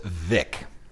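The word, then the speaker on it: Veeck: Now, it's spelled V-E-E-C-H, but it's pronounced Vic.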